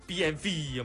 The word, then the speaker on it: BMW: 咁啲人就話其實佢嘅名係應該用德文發音，所以個 W 嘅發音係類似 V 嘅發音咯。 – 係即係應該係讀 BMV 咁樣。